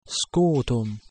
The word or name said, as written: scutum